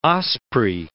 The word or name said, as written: osprey